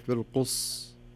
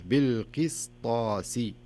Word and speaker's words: بالقسطاس: فتصبح بالقص قص والصحيح بالقس تنطق السين بحيث أنك يعني يسمونها إتمام الحركات أو إتمام الحروف بصفاتها ومخارجها بالقسطاسي ما أقول بالقص لا بالقسطاسي